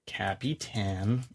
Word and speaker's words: Capitan: Capitan. uh, please pronounce it correctly.